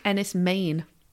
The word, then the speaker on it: Enys Men: And it's apparently pronounced Enys Men. – Enys Men. I think I knew that. Yes, dang it. Okay, Enys Men is how I shall say Now we've both learned some Cornish.